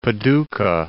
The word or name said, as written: Paducah